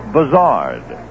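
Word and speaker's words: Buzhardt: And after John had been in the league about a year or two, the Phillies suddenly announced that he wanted his name pronounced Buzzard.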